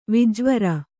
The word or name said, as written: ವಿಜ್ವರ